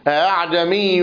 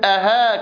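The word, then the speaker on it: أأعجمي: وعندنا في رواية حفص عن عاصم: أعجمي وعربي، وليست أها كما ينطقها البعض، فهي بين الهمزة والألف، ولكنها ليست همزة وليست ألفة، وفي نفس الوقت ليست هاء.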